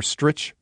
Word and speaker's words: Stritch: He wrote it out here, Stritch rhymes with itch, just to let me know that, so I wouldn't mess that one up.